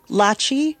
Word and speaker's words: Lachi: So my name is Mubeen, and I just want to check I'm saying your name correctly. – Lachi like Versace, girl. – Lachi like Versace. I love that. – I'm Lachi, she, her, black girl, cornrows, brown skin, big city race.